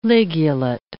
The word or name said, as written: ligulate